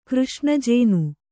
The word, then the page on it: ಕೃಷ್ಣಜೇನು: Kannada